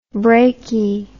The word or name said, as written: brakie